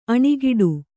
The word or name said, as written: ಅಣೆಕಟ್ಟೆ